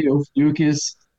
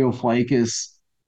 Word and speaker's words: Ophiuchus: So, early on in the book, you talk about something called the Order of I can never pronounce this right. Ophiuchus, is it? – Yes, some say Ophiuchus. some say Ophiuchus.